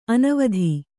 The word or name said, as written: ಅನುವಾದ